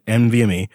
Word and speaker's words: NVMe: So the base configuration starts at 16 gigs of RAM, 250 gigabyte NVMe. I said that right this time, right? Didn't I? – Yes.